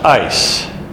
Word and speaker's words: eis: And if you're listening on tape, that does not stand for ISIS. It is spelled E-I-S, ice.